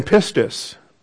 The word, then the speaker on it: pistis: That's the verb, P-I-S-T-E-U-O, long O with the omega. And then pistis. P-I-S-T-I-S.